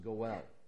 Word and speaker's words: goel: The word translated kinsman in the Hebrew is a word that's pronounced goel.